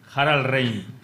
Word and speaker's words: Harald Reinl: Bueno, como es alemán, pero dentro de los nombres alemanes creo que no es muy difícil. Harald Reinl. – Harald Reinl.